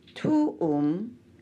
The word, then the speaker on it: tuum: Tuum is pronounced tuum. T-O-L-U-M and not tuum. T-O-O-M.